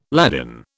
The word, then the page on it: Ladin: British English